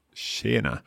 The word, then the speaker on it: tjena: Tjena. Oh, there's a sh sound. Can I guess? Would that be SK? – Nay. It's one of those few ones that is spelled with a TJ. – Right. So TJENA. Tjena.